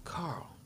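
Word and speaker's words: Karl: Carl Douglas. Yeah. It's Carl with a K.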